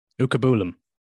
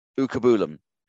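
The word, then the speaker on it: Uchegbulam: Also, I am I'm not on the radio, and I'm quite glad because I don't want to have to try and butcher the name. Jesurun Uchagbulam. – Ukabulam. – Ukabulam. – Yeah.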